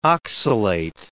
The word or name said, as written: oxalate